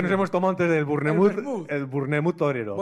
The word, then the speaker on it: Bournemouth: Bournemouth, ¿vale? Que sé que se pronuncia así porque... – ¿Cómo? – Bournemouth. Sí, porque hay un equipo de fútbol.